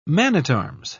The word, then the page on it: man-at-arms: British English